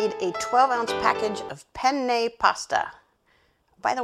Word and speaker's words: penne: And yes, I'm saying penne funny because I live with a Sicilian and I'm told that you have to pronounce both N's.